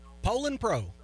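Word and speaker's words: Poulan Pro: And if anybody's interested in them, I've also got a polar, I think I'm saying it right, chainsaw. – Poland Pro. – Poland Pro. Thank you.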